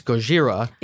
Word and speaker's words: Gojira: But every time Dr. Sarazawa says Godzilla, he says Gojira, which I thought that was an accent thing, having heard it, but it is spelled differently.